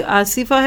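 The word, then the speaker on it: आसिफ़ा: आइन से कहेंगे तो ये तो तूफ़ानी हवा हो गई, तो अगर आपके जानने वालों में कोई आसिफा है तो उसको आसिफा कहिए, आसिफा कहेंगे तो वो फिर ज़ोर की हवा और अच्छे मानों में नहीं रह जाता।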